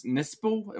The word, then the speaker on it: Nispel: So it's Friday the 13th from 2009, rated R, directed by Marcus Nispel. Am I saying that correctly? – That sounds right.